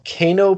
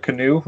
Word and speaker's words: Kano: Sure, it's not canoe. We already talked about that last week. – I was going to say, only one that pronounced canoe? – I almost added an E on the end, just to be K-A-N-O. – Kano.